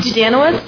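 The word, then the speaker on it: Dudanowicz: Dudanowitz. – Spell? – D-U-D.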